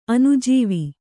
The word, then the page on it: ಅನುಜೀವ: anujīva